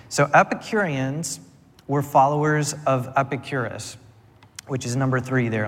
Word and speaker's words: Epicurus: But then there's the Garden of Epicurus, who you might not, I didn't even say that right, Epicurus, and the Painted Porch of Zeno.